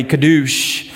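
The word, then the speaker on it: kadosh (קדוש): In the Hebrew, we transliterate this word, just simply pronounce it phonetically, kadosh,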